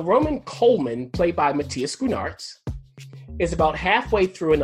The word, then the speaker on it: Schoenaerts: And then it's starring Matthias, how do you say this guy's last name? Matthias Schoenaerts.